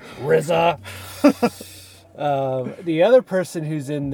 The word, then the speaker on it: RZA: The RZA. Did I say that right? – I think you did. – Whew. Good thing. I feel like I've mispronounced it in the past in other movies that the RZA has been in. – Although it might just be RZA yeah. – at this point, not the RZA.